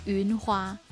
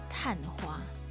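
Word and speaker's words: 昙花: OK，然后这个字我就念错，我把它念成云花，我以为说就天上的云花，但其实那个字念探花。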